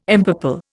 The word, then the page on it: empurple: Indian